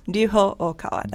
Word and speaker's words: Ryuho Okawa: Ryuho Okawa, that's how it's Ryuho? – Okawa.